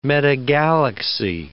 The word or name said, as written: metagalaxy